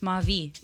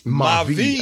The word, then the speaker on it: Ma Vie: It's in French. It's Ma Vie. – Ma Vie.